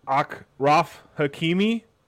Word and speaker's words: Achraf Hakimi: I'm going to probably butcher his name, but Achraf Hakimi, Muslim King.